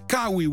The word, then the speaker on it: Kaui: Je ziet gewoon koe op het beeldscherm staan en dan denk ik aan koe, maar het is Kaui.